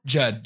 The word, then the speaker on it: Judd: It was, and it's, is it Jude or Judd Apatow? – Judd Apatow.